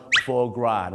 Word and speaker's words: foie gras: foie gras นะฮะ foie gras ไม่ใช่ฝอยกราสนะ